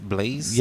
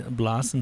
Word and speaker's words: Blaise: And is it pronounced Blaise? – Yeah, it's pronounced Blaise. – Blaise. No. Or Blaise. Blas in Spanish, Blaise in English.